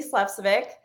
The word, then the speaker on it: Slepcevic: So my name is Tracy Slepcevic. Thank you for pronouncing that right.